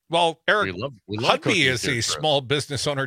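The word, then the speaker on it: Hovde: Well, when you talk about the best and brightest from Wisconsin, I'm less clear that either Eric Hudby, and I've learned, I heard his ad last night, it's Hudby. – Hudby, yes. – I was calling him Hudby, but, you know, yeah, emphasis on the ho, like sellout, you know, Laguna Beach sellout ho.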